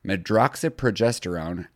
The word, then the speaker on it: medroxyprogesterone: Like, chemical castration involves injections of, again, I'm going to butcher this, medroxyprogesterone.